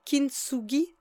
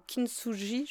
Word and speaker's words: kintsugi: Alors, j'espère que j'ai bien le prononcé, c'est Kintsugi ou Kintsugi, je ne sais pas comment faut dire. – Je pense que c'est Kintsugi, en fait, qui vient de l'art japonais de réparer des objets avec de l'or.